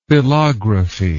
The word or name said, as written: philography